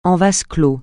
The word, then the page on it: en vase clos: français